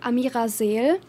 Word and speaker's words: Amira Seel: And my name is pronounced like Amira Seel, but it's okay if you pronounce it like in American English.